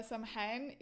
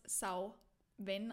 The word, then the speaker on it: Samhain: Man spricht theoretisch oder eigentlich spricht man es Sauwen aus. Sauwen, jetzt habe ich es falsch gesagt. – Sauen. – Sauwen. Du sagst es. – Sauen. – Sauwen, genau, Genau. – Sauwen. Man kann aber auch Samhain sagen.